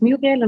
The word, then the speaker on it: Muriel: Also wie du schon gesagt hast, äh, mein Name ist Muriel und du hast es sehr richtig ausgesprochen.